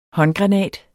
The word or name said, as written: håndgranat